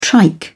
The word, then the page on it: trike: British English